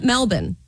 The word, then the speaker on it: Melbourne: Like just unbelievable that they tried to pull off a game after just landing in, I'm going to call it Melbourne. Because that's how they say it there.